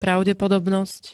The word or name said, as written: pravdepodobnosť